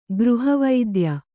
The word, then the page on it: ಗೃಹವೈದ್ಯ: gřha vaidya; Kannada